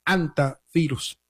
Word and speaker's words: hantavirus: Se podría pronunciar también con la H muda, por supuesto, y también es válida la pronunciación haciendo el énfasis en la primera sílaba con la letra J de Hanta, aunque también es válida la forma hantavirus.